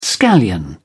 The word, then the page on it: scallion: British English